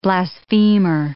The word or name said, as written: blasphemer